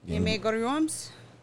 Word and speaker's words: Game Grumps: Game Grumps.